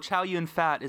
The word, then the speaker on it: Chow Yun Fat: It is Mark Wahlberg and Yun Fat Chow. And that's cool. Chow Yun Fat is actually the correct way to say that.